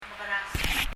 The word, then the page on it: mekerasem: mɛkɛrəsəm